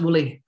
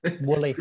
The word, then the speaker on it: Willie: Willie Rennie? – Yeah, well done, Paul. It was Willie Rennie. He won Dunfermline and West Fife from Labour in 2006. – It's pronounced Woolly. – Wooly.